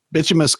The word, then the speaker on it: bituminous: I did strike bituminous coal. Bituminous coal, bituminous coal. – Yeah, perfect.